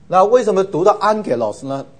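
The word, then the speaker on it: Angelos (ἄγγελος): 在希腊文 double gamma 呢，它会变成是这个发音，是 NG 的发音，所以 N 是这个发音，所以变得是 Angelos double G 它会变成的发音呢，因为两个 G 很难都是重都是喉音后面，所以很难发，所以就把前面一个变成是鼻音啊，new 啊，所以发音是 angelos 啊，天使或是 messenger，angel or messenger。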